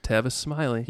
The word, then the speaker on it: Tavis Smiley: Tavis Smiley, is that how you say his name? – Yeah, Tavis Smiley.